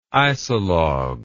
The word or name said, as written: isologue